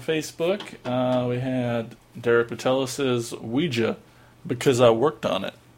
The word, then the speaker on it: Ouija: It's Ouija. – It's... French for yes and German for yes.